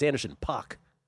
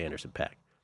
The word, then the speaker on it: Anderson .Paak: By the way, it's Anderson .Paak. I've been mispronouncing it. I always say Anderson .Paak.